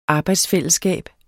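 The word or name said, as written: arbejdsfællesskab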